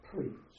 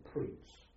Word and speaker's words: Preetz: I went to a place called Church House at Preetz, a place called Preetz.